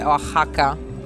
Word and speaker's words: Oaxaca: Si scrive Osaka, no, si scrive Oaxaca, però si legge Oaxaca.